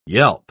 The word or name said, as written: yelp